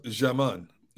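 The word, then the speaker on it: Djimon: 59 today is Dijmon Hamsu. – Yep. – I think I pronounce it. He's an actor. – It's pronounced Jaman. – Jaman Hansu. – Hansu.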